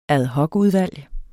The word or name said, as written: AD